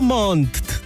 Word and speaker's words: Montt: Si eres de Puerto Montt o alrededores, no te puedes perder este hermoso panorama, los temporales internacionales de teatro de Puerto Montt. – Bien, es que hay que pronunciar las dos T.